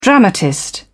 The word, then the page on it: dramatist: British English